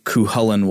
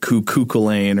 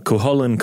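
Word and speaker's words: Cú Chulainn: Now, Robert, I admit before we did this episode, I had no idea who Cuchulainn was, and I probably would have pronounced it like Cuculain or whatever.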